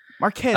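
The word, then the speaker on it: Marques: Marquez.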